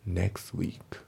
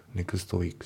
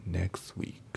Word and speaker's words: next week: で、発音ですけど、 next の t が抜けますね。 next week。 next week じゃなくて、 next week。